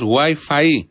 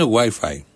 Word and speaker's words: WiFi: Βεβαίως εδώ και εσείς το τονίζετε αλλιώς. WiFi λέτε πολύ, όχι, το σωστό είναι WiFi.